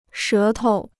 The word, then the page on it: 舌头: shé tou